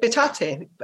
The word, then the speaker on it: Bitate: This clip is the time that Bitate, sorry, is it Bitate? How do you pronounce it? – Bitate. – Bitate.